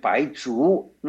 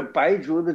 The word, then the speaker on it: 白术: 不是，白术的术啊，我们现在在中国大陆是念成白术，他说是这个做手术那个术。其实本来是念白术才是对的，但是呢我们现在常常说，因为这个术跟术它用同一个字了，所以大家都会念成白术去了。